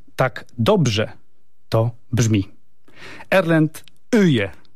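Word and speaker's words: Øye: Odpowiedziałem, Erlend Öje. – Öje, yes, Öje, very good, very good.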